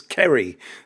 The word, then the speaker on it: Ceri: It's like my name's Kerry, C-E-R-I. It's four letters. How many problems can that cause for people? Well, a lot. People call me Seri, Cherry. How do you pronounce this?